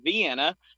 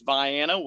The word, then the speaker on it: Vienna: Of course, whenever people talk about Vienna, they want to pronounce it Vienna because that's how you would normally pronounce it. But in the state of Illinois, it's Vienna, way down in the southern tip.